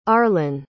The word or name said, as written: arlan